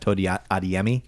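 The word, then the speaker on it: Tomi Adeyemi: That's because it's actually pronounced Tony Adeyemi.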